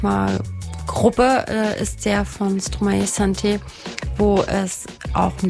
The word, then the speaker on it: Stromae: Wie spricht man den eigentlich aus? – Stromae. – Stromae. Stromae. Okay.